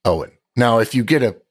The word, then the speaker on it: Eoin: I always stumble, for instance, on the Irish name Owen, which is spelled E-O-I-N. And I always think it's like Eowyn.